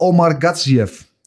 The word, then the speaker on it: Gatsiev: Boraljo tegen Omar Gatsiev. Zeg ik dat goed zo? Dat zeg je heel goed.